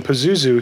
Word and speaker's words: Pazuzu: P-A-Z-U. – Pazuzu. – P-A-Z-U-Z-U. Pazuzu. – Pazuzu. – The Exorcist used Pazuzu totally wrong. – Yeah. – If you go back and look up the demon Pazuzu, he was a protector of women and their children.